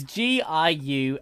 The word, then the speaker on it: Giuffre: I think I know how to pronounce this. Virginia Giuffre? I want to say Giuffre. It's Giuffre. Virginia Giuffre.